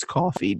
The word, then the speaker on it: Ko-fi: If you like these takes, please do feel free to tip me on Ko-Fi, which I refuse to pronounce coffee despite their branding.